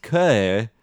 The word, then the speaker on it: que: It's a French saying, so it's K pronounced que, as opposed to the Spanish K pronounced K.